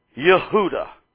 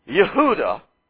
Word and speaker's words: Yehuda: Yehuda is the name of one of the tribes of Israel in Hebrew. You're used to pronouncing it Judah. Do you see the connection between Jew and Judah? The first syllable of the one and the only syllable of the other sound exactly alike.